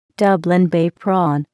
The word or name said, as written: Dublin Bay prawn